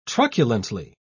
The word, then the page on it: truculently: British English